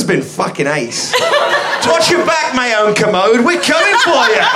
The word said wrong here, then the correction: Kermode: It's pronounced Kermode. – Kermode, yeah.